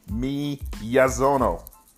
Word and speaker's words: Miyazono: Number nine, Brandon Mianzono, Frisco High School. Let me say that again. Miyazono.